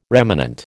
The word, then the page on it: remanent: American English